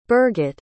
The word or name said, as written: birgitte